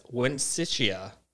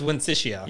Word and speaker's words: Wensicia: No doubt to the outrage of the Princess Wensicia. – Wensia. – Wensia? – Yeah. I have always read that as Wensia. – Yeah, it's Wensicia.